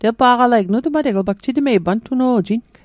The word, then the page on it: pantuno'jing: Mi'gmaq